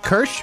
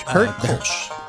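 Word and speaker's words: Kölsch: All right, so we try this with the, you called it the Kirsch? – Kolsch.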